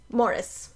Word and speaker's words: Maurice: But yeah, that's another interesting thing that throws me off is that in the UK, the name that we would pronounce Maurice is Morris.